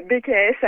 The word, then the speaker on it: BKS: Et puis un petit peu plus tard, à 23h, il y aura, alors je ne sais pas comment on prononce, James BKS ou BKS. – Voilà, BKS à la française.